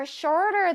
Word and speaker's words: shorter: Shorter 할때 발음이 조금 어려워서 one more time. Shorter. Shorter. 그쵸. 쇼롤 같죠? Shorter.